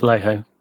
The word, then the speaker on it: Vallejo: Now, and that came of CeCe Sabathia, who is a product of, I think it's Vallejo. I think that's how you pronounce it. – Vallejo. Yep.